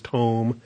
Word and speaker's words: tom: It looks like Tom, but it's a long O, so it's tome. Anybody named Tom, don't get carried away. It's tome, okay?